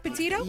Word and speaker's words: Petito: Am I pronouncing her name right, Petito? – Yeah.